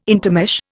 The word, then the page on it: intermesh: Indian